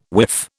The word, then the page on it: whiff: British English